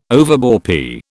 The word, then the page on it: overbore: British English